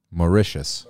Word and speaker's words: Mauritius: Mauritius.